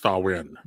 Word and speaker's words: Samhain: Yeah, it, it spelled Samhain, but it's pronounced Samhain.